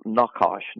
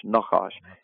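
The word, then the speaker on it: Nakash (נָחָשׁ): Now, in the Hebrew, it would be pronounced N-A-W-K-A-S-H. So Nakash. Nakash. And there's a little bit of a guttural sound in between there, those syllables.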